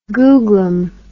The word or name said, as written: googlum